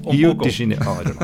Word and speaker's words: Rio de Janeiro: And maybe because I have a few words of Portuguese, I should try and pronounce Rio de Janeiro the way the Brazilians pronounce it.